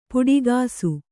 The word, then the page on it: ಪುಡಿಗಾಸು: puḍigāsu